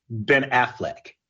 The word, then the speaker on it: Affleck: Ben Affleck.